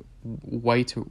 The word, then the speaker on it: Wight: We get the introduction of a new character in here who I believe is called White White Witch, but it's spelled like W I G H T.